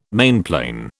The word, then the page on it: mainplane: British English